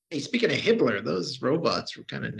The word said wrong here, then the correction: Himmler: But, it's pronounced Himmler.